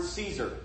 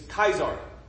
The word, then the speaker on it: Caesar: The one example is the Latin word Caesar. We give it a soft C. Sounds like an S. The ancient Latin gave it a K sound. A K. He was Caesar.